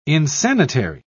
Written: insanitary

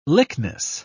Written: lychnis

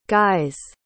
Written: guys

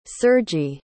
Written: sergiy

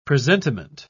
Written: presentiment

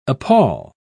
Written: appal

